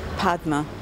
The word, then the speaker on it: Padma: E-L-E-N-A. – Where are you from? – Brazil. – What's your name? – Padma. – How do you spell it? – P-A-D-M-A.